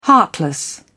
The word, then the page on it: heartless: British English